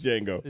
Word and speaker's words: Django: The D is silent. – Yeah. Yeah. It's just Django.